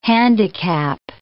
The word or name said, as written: handicap